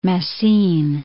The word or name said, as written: Massine